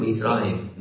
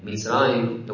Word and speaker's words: מצרים: How do you say Egypt in Hebrew? Mitzrayim. Mitzrayim, the word Mitzar, it means to be closed, it means to be in pain.